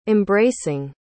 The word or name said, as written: embracing